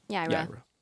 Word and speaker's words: Yaira: And that's how it's pronounced, not Yara. – Do it. – Do it. – Yaira? – Yaira.